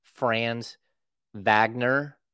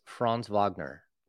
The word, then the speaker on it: Franz Wagner: Brendan actually like pronounced the names correctly. It's not Franz Wagner, like it's Franz Wagner.